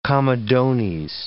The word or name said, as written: comedones